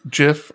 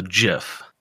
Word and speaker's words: GIF: GIF, J-I-F, the peanut butter is pronounced GIF. G-I-F yeah. is GIF.